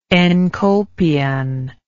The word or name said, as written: enkolpion